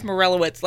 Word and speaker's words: Morelowitz: No one can pronounce his last name because it's not spelled how it's pronounced, but it's Morelowitz, like the mushroom.